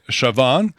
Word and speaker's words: Siobhan: Ch ça s'écrit Siobhan. Fait que quand tu es annonceur à la piscine, puis une plongeuse qui s'appelle Chevonne, ben les gens qui ne savent pas, Siobhan Carter.